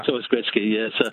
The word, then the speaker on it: Gretzky: Wayne Gretzky has always been pronounced Wayne Gretzky, and I suspect Michael just wasn't familiar with how to pronounce it when he read it off the whatever he was reading off.